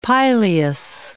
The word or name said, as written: pileus